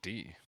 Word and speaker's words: die: How to pronounce die in German. Oh, you might be right. D. Hang on, let me watch this YouTube video about it, too. – Is it a long YouTube video about the history of the pronunciation? – Damn it, it is D, Alex.